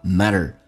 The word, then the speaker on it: matter: 最后一个 matter T 变成 D 这个发音，两个元音中间所加的辅音要被浊化， matter。